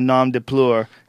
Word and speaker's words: nom de plume: He is the only semi-white dude who's allowed to have a nom de plume. And we, is that how you say it? – That's right.